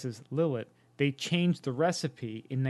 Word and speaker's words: Lillet: And one thing that he talked about is that the Kina Lillet, or I don't know if it's pronounced Lillet. – Probably, oh, it's Lillet, yeah.